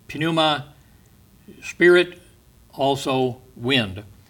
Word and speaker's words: pneuma: So I have to clue you in that the word Spirit in the Greek is pneuma. Now, we use that word for pneumonia, pneumatic hammer, and so on. We don't pronounce the P, but in the Greek you do. Pneuma.